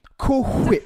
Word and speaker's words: Cool Whip: Cool Whip. – It's a food product. – And it's pronounced Cool Whip. You got to put emphasis on the H.